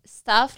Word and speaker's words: staff: Entonces, ¿cómo se dice S-T-A-F-F? – Es que tienen que pronunciar el AF. Staff, company staff. – Staff. – Mejor. – Y ahora S-T-U-F-F.